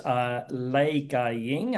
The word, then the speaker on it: Lei Gaiying: And she is Lei Gaiying, that's how it's pronounced in Cantonese.